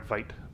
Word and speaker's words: Veidt: Veidt. I say Veidt because it's spelled the same way as Veidt from Watchmen. – Yes, you are correct.